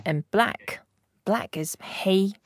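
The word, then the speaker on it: hey (黑): And hey is very easy to pronounce. It's like hey. – Okay. – And yeah, without getting so excited. – Yeah. – And then just hey is the first tone, so it can be flat and very cool.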